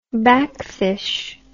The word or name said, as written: backfisch